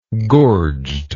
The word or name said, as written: gorged